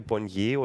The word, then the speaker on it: Bonnier: Und da, letztes Jahr gab es eben eine Ankündigung von drei großen Häusern, dem schwedischen äh, Verlagshaus, wo ich immer nicht weiß, ob ich es richtig ausspreche, Bonnier oder Bonnier. Und also Schwedisch, Für Bonnier. Französisch, ja.